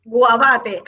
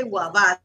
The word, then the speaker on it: Guavate: It's called Guavate, where the pork. Guavate. I would say Guavate. – How do you spell that? – G-U-A-V, as in Victor, A-T-E.